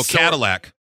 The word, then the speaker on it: Cadillac: I'm sorry. Shadillac. – No, Cadillac. – Oh, sorry. – The H is silent.